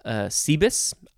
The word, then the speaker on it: Cebus: The genus name is Cebus. I'm going to say that's how it's pronounced, C-E-B-U-S.